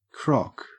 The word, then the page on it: crock: Australian English